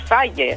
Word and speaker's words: Faye: Si jo us dic bé ara el francès perquè no recordo gaire les pronunciacions, però és F-A-Y-E. – El buscarem, el busquem. – Jo diria que és Faye. – Sí, mira, Gael Faye. Així, exactament.